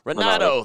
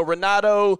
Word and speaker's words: Renato: That's how you say it, Renato or Renato, yep. – Moicano? – Renato. – There you go. Renato.